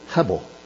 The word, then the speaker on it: Hebel: In Genesis, it gets pronounced Abel in our English Bibles, but in Hebrew, it's the same word, Hebel.